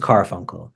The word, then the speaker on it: Karfunkel: And I say, you have to make sure you're pronouncing the names correct, because my name is Karfunkel, and you're going to call me up as Garfunkel, then I don't care.